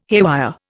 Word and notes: haywire: Indian

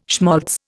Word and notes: schmaltz: Indian

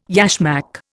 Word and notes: yashmak: Indian

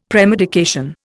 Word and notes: premedication: Indian

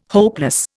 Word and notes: hopeless: Indian

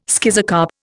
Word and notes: schizocarp: Indian

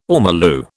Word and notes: ormolu: British English

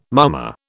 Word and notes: mummer: British English

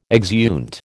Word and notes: exeunt: American English

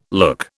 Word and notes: look: British English

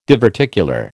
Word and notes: diverticular: American English

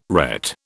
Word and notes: ret: British English

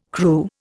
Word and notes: Kru: Indian

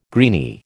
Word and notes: greeny: American English

